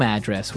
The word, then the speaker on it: address: So different from your home address where you live. – Yeah. And there the stress is on the first syllable. – Address. – Right.